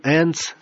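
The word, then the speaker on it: Enz: Это оказался презерватив Троян Энц со смазкой и специальным резервуаром. Произносится как ЭНЦ», — глядя на протоколиста, добавил он.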